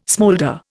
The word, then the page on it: smoulder: Indian